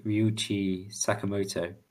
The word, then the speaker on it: Ryuichi Sakamoto: So that's basically Ryuichi Sakamoto, or whatever. Is that how you pronounce his name? – Yeah.